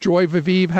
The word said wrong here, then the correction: joie de vivre: How do you say that? – Joie de vivre. – Joie de vivre. Thank you.